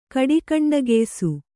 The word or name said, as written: ಕಡಿಕಂಡಗೆಯ್ಸು